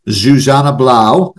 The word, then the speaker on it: Zsuzsanna Blau: So this week, really, really pumped to have Zsuzsanna Blau, and I've been working on that, right, from a pronunciation.